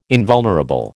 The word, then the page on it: invulnerable: American English